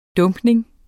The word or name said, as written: dumpning